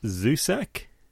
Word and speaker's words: Zusak: By Marcus Zusak.